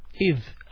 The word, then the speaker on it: إِذْ: إِذْ, alif, hamza under the alif, and kasra, so we pronounce it E, not A.